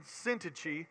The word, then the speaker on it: Syntyche: and we believe possibly that Euodia and Syntyche, which is, if you don't know how to pronounce those, Euodia and Syntyche, were possibly even part of that group.